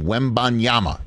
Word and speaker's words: Wembanyama: Wembanyama. That's how you pronounce his name, Wembanyama.